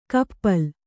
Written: ಕಪ್ಪಲ್

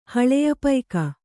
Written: ಹಳೆಯಪೈಕ

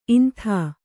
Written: ಇಂತಿ

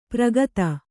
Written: ಪ್ರಗತ

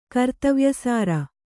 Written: ಕರ್ತವ್ಯಸಾರ

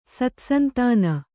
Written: ಸತ್ಸಂತಾನ

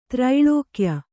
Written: ತ್ರೈಲೋಕ್ಯ

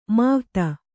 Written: ಮಾವ್ತ

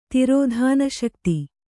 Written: ತಿರೋಧಾನಶಕ್ತಿ

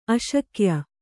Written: ಆಸೆಗೆಯ್

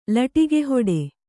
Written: ಲಟಿಕಿಹೊಡೆ

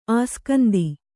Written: ಅಸ್ಕಂಧ

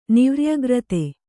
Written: ನಿವ್ರ್ಯಗ್ರತೆ

